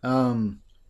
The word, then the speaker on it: Demme: The first thing I want to say has to deal with Jonathan Demme. – Is that how you pronounce it? I was curious. – Yeah, it's okay. Demme.